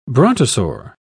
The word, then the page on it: brontosaur: British English